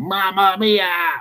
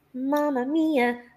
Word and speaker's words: Mamma Mia: That every time something big happens, he screams out, Mamma Mia! It's just fucking annoying. – Because he's not saying it right. – He's not. – Mamma Mia. That's how he needs to say it.